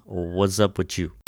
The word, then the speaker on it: Whatzupwitu: No, it's called What's Up With You. – That's the official title spelled as it was then pronounced.